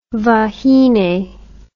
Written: vahine

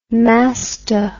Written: masto

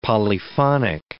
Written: polyphonic

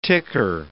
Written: ticker

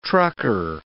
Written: trucker

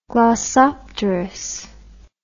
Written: glossopteris

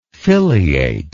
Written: filiate

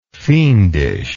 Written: fiendishness

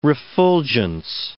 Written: refulgence

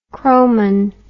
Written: chromon

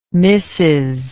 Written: Mrs

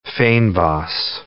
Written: fynbos

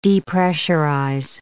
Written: depressurize

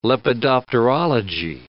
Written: lepidopterology

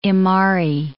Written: Imari

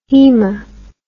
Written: hema

